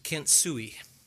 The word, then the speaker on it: kintsugi: It's pronounced kintsugi. It's spelled K-I-N-T-S-U-G-I, kintsugi.